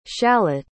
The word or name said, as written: shalott